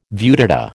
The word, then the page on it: viewdata: American English